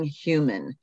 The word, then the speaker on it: Heumann: So the title is a play on her last name because it's Being Heumann.